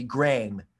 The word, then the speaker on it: Graeme: I mean, I would have pronounced that Graham, like the name Graham, which it is, but it's written in a British way, so it's supposed to be Graeme.